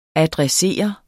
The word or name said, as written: adressere